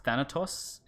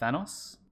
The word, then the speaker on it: Thanatos: He makes it clear that it's pronounced Thanatos and not Thanos.